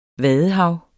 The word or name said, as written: VADEHAVET